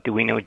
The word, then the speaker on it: Doino: And that one, if you look that up under my name, my last name is spelled Doino, D-O-I-N-O,